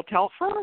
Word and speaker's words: Telfer: So is his name Paul Telfer? Is that how you pronounce his last name? – Yeah.